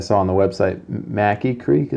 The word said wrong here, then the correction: Mackay: Is that how you say it? – Uh, Mackay. Mackay. Yeah.